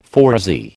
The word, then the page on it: phoresy: American English